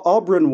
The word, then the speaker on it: Auberon: First of all, how do you pronounce A-U-B-E-R-O-N? Oberon? Aubryn? – Oh, Aubryn Waugh, known as Braun to his friends, of which I was not one, alas, because he's hilarious.